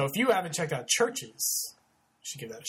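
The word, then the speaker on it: CHVRCHES: I've been listening to Churches lately, which is stylized as Churches with a V instead of a U. It's pronounced Churches, but it's spelled with a V.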